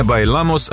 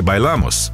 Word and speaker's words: bailamos: Забавно, что авторы «Bailamos», британцы, вообще не говорили по-испански и неправильно расставили ударение. В слове «bailamos» оно должно падать на второй слог, а не на последний.